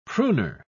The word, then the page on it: pruner: British English